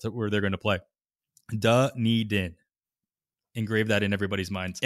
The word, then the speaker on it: Dunedin: And yes, that is how you say Dunedin. – I didn't know. – Yeah. Dunedin.